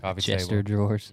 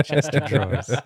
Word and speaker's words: chest of drawers: Chester drawers. – Yeah, that's how you say it, man. Chest of drawers. – So, man, you ain't invited back if you make fun of the way you talk.